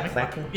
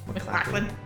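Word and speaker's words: MacLachlan: Yeah, McLaughlin. – Yep. McLaughlin.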